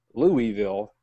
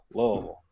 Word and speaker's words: Louisville: So I always tell people, you know you can pronounce the name of our city correctly after you've had two bourbons. – Louisville. – And you call it Louisville.